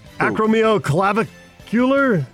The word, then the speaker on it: acromioclavicular: Acromioclavicular? – Good job.